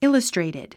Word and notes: illustrated: íləstrèitid; イラストレイティド

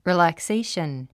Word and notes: relaxation: rìːlækséiʃən; リーラクセイション

